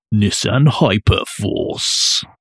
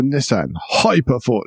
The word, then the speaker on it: Nissan Hyper Force: I don't think you've said that right. Nissan Hyper Force. – Sorry. This is the Nissan Hyper Force.